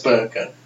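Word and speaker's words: burqa: I say Gurkha, that's... – Oh, no, it's definitely pronounced Burka.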